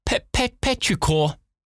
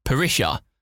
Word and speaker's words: petrichor: No, there's a T. Petrichor.